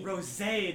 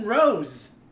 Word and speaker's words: Rosé: Rosé. They have the accent on the E.